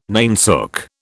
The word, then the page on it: nainsook: British English